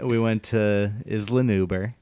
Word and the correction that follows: Isla Nublar: It's pronounced how you pronounce it. – Isla Nublar.